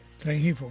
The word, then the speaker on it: Rengifo: Y por último, Rengifo, no sé si se dice así. – Rengifo, correcto.